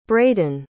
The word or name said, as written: braeden